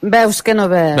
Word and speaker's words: Veus que no veus: Al Teatre de la Llotja, a veure, jo no sé si ho pronuncio bé, veus que no veus o veus que no veus, veus o veus que no veus? – Veus que no veus.